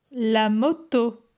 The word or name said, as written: La moto